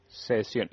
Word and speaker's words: Sezion: Mira, se escribe S-E-Z-I-O-N. – Sesión.